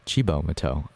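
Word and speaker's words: Cibo Matto: But up next, we're back with Chibo Mateo. I learned that's how it's pronounced in between songs because I looked it up on Google.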